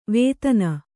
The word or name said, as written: ವೇದನ